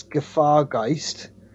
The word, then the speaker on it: Gefahrgeist: I think it is Gefahrgeist, which means danger ghost or danger spirit in German.